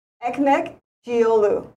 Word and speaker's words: Ekmekcioglu: Okay, so how do you say it again? – Ekmek-Giolu. – Ekmek-Giolu. – Perfect.